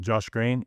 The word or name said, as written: Josh Green